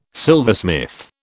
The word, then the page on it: silversmith: British English